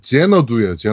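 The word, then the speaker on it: Xanadu: 뭐라고 발음돼 있어요? 발음 기호상? 도원경이고 제 제너두예요. 제너두. – 아 제너두예요. 네. 아 그렇군요. 제너두라고 합니다. 그럼 제너두라고 읽겠습니다.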